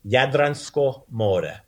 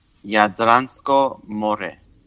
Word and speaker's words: Jadransko more: So we have the Adriatic Sea, and the way you pronounce that in Croatian, you say Jadransko more. – Jadransko more.